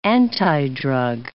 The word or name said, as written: antidrug